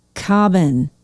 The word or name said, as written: Cobban